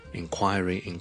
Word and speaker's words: inquiry: 大家都是读 inquiry, 重音在于第二个音节 ,query, so it's inquiry, 前面呢只需要一个很轻的 in, 但如果 N 音呢很多时候受后面的 K 音影响呢就会变成好像 in 咁样嘅 inquiry 就唔需要咧读成 enquiry 嘅，虽然佢嘅串法系 en，咁个原因就系因为佢第一个音节咧佢唔系重音，唔系重音咧，通常咧我 en 咧都唔会读 n 嘅，通常读 in 就可以啦。